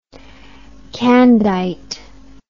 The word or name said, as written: candite